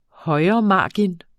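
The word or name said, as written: højremargen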